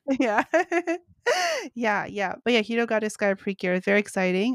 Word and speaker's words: Hirogaru Sky Precure: I believe it's pronounced Hirogaru Sky Precure, I believe.